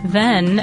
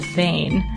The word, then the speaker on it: Ven: During our study of Tycho Brahe, the assigned reading included the puzzling phrase, Tycho Brahe did not die in vain, spelled H-B-E-N. This made sense when we learned that the town in question isn't pronounced Ven, as in your podcast, it's pronounced Vane.